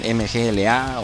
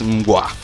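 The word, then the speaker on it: Mgła: esto que acabamos de escuchar es a cargo de la banda Mgwa, los que muchos los llaman MGLA o Migla, pero ya investigué con gente polaca que sí habla polaco porque son polacos y la pronunciación correcta es Mgwa, que significa niebla.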